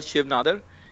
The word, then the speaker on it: Shiv Nadar: Shiv Nadar. I don't know if I'm pronouncing it right. – Yeah. The school is called Shiv Nadar.